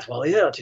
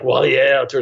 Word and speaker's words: Wallaert: But Americans mostly say it Wallaert. I'm sure it's something beautiful in French, like Wallaert or something, but I don't know.